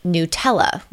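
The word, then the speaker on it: Gnutella: They planned on distributing the software as open source, meaning available for all, and they called it Nutella, with a silent G as the first letter, a pun referring to the famous hazelnut spread, and to GNU, that's GNU, the open source movement.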